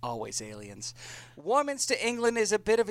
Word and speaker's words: Warminster: Warminster, England is a town of about 17,000 in the county of Wiltshire in southwestern England. – Hey, you said it right, I think. Good for you.